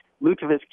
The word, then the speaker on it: lutefisk: Is that the lutefisk? – Lutefisk, yeah. So, and then with lutefisk, so there's rockfisk, which is the buried fish, and then lutefisk. I really researched how lutefisk came into being.